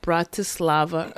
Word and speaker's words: Bratislava: That 24-year-old thief allegedly forced a male employee to hand over money from the cash register at the station in Bratislava early Wednesday.